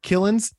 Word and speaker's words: Kilens: And today I have a very special friend, Mark Killens.